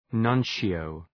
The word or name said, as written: nuncio